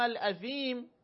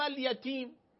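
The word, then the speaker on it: الأثيم: الاثیم، این آیه است، اون آقا اینطور میخون طعام الیتیم، این حرف ف رو نمیتونست تلفظ کنه، میگفت یتیم، هی ابن مسعود بهش میگفت عظیم، اون میگفت یتیم، فرد علیه کل ذلك یقول طعام الیتیم، هی طعام الیتیم میگفت.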